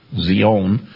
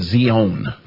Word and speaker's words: Zion: Now, the proper pronunciation in Hebrew is Zion. Zion. And what is a Zion?